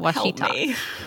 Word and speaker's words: Ouachita: Ouachita. I had to look it up how to pronounce it, and I even did the phonic version of it next to it so I wouldn't forget.